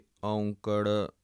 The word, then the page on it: ongkar: Punjabi